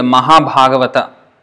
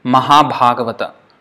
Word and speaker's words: Mahabhagavata: But in Bengali they would say Moha, because the first one A is a short A, so it's O. But the second one is A, so that is A. And Moha Bhagavat. This is how it would pronounce. Moha Bhagavat. But it's Mahabhagavata. So, even if you can't pronounce it exactly like a Bengali, it doesn't matter. If you say Mahabhagavat, it is still not wrong.